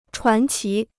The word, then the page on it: 传奇: chuán qí